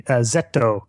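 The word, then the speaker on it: Zeto (ゼット): And I guess just to explain that in Japanese, it is Z, Zeto, which is how the Japanese pronounce the English alphabet letter Z.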